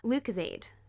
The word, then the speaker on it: Lucozade: I am opening Lucozade. Pronounced that right, yeah? Mm-hmm.